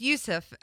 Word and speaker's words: Yusuf: is it Yusuf or Jusuf Nurkic? Jusuf Nurkic? I don't know. Burns. I don't know. – It's Yusuf.